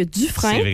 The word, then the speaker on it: Dufresne: Durham, on le prononce encore en anglais, alors que Dufresne, on le dit en français.